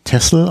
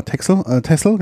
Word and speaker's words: Texel: Aber es wird eigentlich Texel ausgesprochen. Genau, Texel, genau. – Texel, wenn man so schreibt.